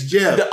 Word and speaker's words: GIF: I don't know what the official pronunciation of GIF is, but the graphics industry pronounces it GIF with a hard G, same as G is in graphics. I've been in the industry for 22 years, and that's how we all pronounce it. But the guy who made it said it's Jeff.